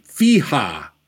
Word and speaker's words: FEHA: Prominent is the FEHA. That's the way I pronounce it, the F-E-H-A, the Fair Employment and Housing Act.